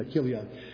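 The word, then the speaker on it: Chilion: So, Kilion, it's pronounced, yeah, Kilion. The CH actually takes a K there.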